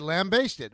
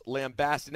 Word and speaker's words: lambaste: It's pronounced lambasted. Now, lambaste is the word. But once it becomes... – Lambaste. – Once it becomes past tense, it becomes lambasted.